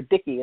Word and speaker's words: Dike: She was a virgin goddess of innocence and purity, and is always associated with the goddess of justice, which is Dike, or Dicky is another word they pronounce her name.